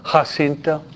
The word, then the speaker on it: Jacinta: Jacinta or Jacinta, J-A-C-E-I-N-T-A. Spanish is pronounced Jacinta. You aspirate the J in Espanol.